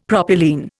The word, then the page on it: propylene: Indian